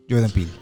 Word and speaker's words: Jordan Peele: Jordan Peele.